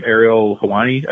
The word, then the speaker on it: Ariel Helwani: I was watching that Sami Zayn interview earlier this week, well, late last week, technically now, with, and I'll mispronounce his name, Ariel Helwani, I believe is the guy's name.